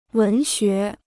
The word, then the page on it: 文学: wén xué